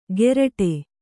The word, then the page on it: ಗೆರಟೆ: geraṭe